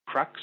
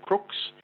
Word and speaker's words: Crux: The official name for that constellation is Crux or Crooks, if you want the correct pronunciation.